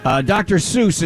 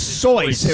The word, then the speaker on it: Seuss: Dr. Seuss is not Dr. Seuss. – He's sus, right? – Soyce. No. It's Soyce. It rhymes with voice. That when he conceived it in college, that was the way he pronounced it.